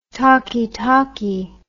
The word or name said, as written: talkee talkee